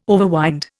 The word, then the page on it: overwind: Indian